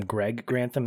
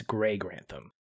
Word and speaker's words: Gray Grantham: And I would always, when I watch this movie, I would always call him Greg Grantham instead of Gray. It's not Greg, it's Gray Grantham.